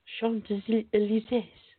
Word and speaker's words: Champs-Élysées: S-E-E-S. Champs-Élysées. – Champs-Élysées.